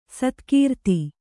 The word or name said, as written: ಸತ್ಕೀರ್ತಿ